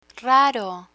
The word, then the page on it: raro: Spanish